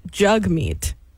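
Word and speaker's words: Jagmeet: but he has said his name's not Jag. Don't call him Jag. – Do you know why that he doesn't like being called Jag? – Go on. – Is because it's pronounced Jugmeat.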